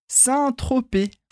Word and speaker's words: Saint-Tropez: Então, só cuidado aqui com a pronúncia do R, porque em francês, claro, vai ficar Saint-Tropez.